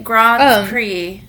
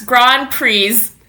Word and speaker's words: Grand Prix: Oh, I think you actually say it, Grand Prix. Oh. But you only say it if you're pretentious. Otherwise, it's Grand Prix.